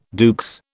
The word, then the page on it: à deux: British English